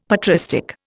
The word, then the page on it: patristic: Indian